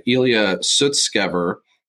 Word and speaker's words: Ilya Sutskever: Then about an hour ago, there was new information that just popped out that, let me get the pronunciation right, Ilya Sutskever had flipped on the board to say,